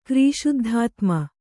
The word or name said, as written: ಕ್ರೀಶುದ್ಧಾತ್ಮೆ